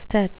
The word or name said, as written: stet